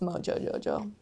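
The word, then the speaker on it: Mojo Jojo: I'm Ro-Jo-Jo-Jo. – Oh, I've never really... – Sorry, I said it wrong. It's Mo-Jo-Jo-Jo.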